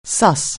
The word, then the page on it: suss: anglais